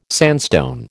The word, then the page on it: sandstone: American English